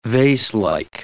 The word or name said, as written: vaselike